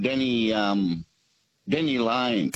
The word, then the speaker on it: Denny Lane: Denny Lane. – Rhymes with Penny Lane.